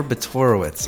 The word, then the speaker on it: Botorowicz: Harper Botorowicz.